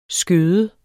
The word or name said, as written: skøde